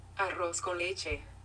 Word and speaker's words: arroz con leche: Arroz con leche. – I don't think I can roll my R like that. – Arroz con leche.